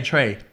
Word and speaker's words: Tre: I say Trey. T-R-E.